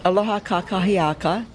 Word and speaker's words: Aloha kakahiaka: Aloha kakia. Blah. Gotta pronounce it correctly. Aloha kakahiaka. That's good morning in Hawaiian.